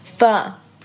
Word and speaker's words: Pho: So, jetzt kommt die, die vietnamesische Aussprache. Eigentlich hat, hat Wietz mir beigebracht. Pho. – Pho. – Pho. Pho-Suppe. Oder halt eben Pho-Suppe. Oder Po-Suppe. – Ich glaube, es ist äh, eher Pho-Suppe.